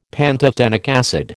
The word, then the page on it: pantothenic acid: American English